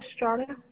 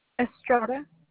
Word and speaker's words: Estrada: Della, D-E-L-L-A. – All right. Your last name? – Duran, D-U-R-A-N hyphen Estrada. – You said D-U-R-A-N hyphen what again? – Estrada. – And you spell that? – E-S-T-R-A-D-A.